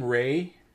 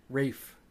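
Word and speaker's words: Ralph: Pronounces his name Ray. – Rafe.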